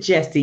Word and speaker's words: Yesse: That's yes with with Yesse because it starts with the Y. I pronounce it Jesse.